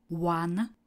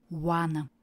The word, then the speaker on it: won: A jeho tři tvary zní win, one, one. Tady je výslovnost úplně stejná jako u číslovky jedna. One.